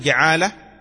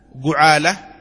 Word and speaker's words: جعالة: قال لا يستحق المؤجر الأجرة ولا المجعول له الجعالة، يصح أن تقول جعالة، جعالة، جعالة، كله صحيح، بفتح الجيم وكسرها وضمها، جو جا جي، تمام؟